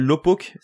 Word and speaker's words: Laupok: D'ailleurs, je vous invite à regarder une vidéo d'un YouTuber qui s'appelle Lopok. Ça s'écrit L-A-U-P-O-K, où il parlait justement des d'autres personnes qui ont été prises en flagrant délit de tricher sur d'autres jeux.